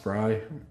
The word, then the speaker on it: Frey: Is her opponent's last name really pronounced Fry? – I think it's pronounced Fry, but it's spelled Frey, right? F-R-E-Y.